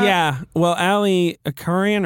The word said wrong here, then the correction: Arikan: or how do you say his last name, actually? Do you know that guy? – I don't know. – Ali Arikan. Arikan.